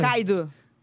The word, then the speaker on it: Kaido: Kaido.